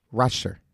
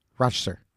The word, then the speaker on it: Rochester: They say it like smoother, faster. Rochester. Rochester.